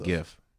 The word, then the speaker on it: GIF: GIF. The hard G.